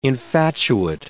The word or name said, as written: infatuate